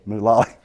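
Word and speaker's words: Mulally: But he saw in this gentleman, Mullally. Did I say it right? – Mullally. – Mullally. Okay.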